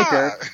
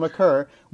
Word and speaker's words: Maker: Good pronunciation. That is the real pronunciation, everybody. Ah! Everyone says Maker, but it's Thon Maker.